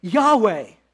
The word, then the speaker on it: Yahweh: It's pronounced, many Bible scholars believe, in Hebrew as Yahweh.